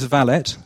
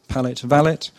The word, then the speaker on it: valet: valet. Sorry, I always pronounce that wrong, apparently. Apparently, the correct pronunciation is valet. So, excuse me, it's supposed to rhyme with palette, valet.